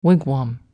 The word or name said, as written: wigwam